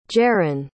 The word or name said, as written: Jerrin